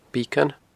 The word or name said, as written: Beacon